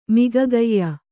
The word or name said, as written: ಮಿಗಗೈಯ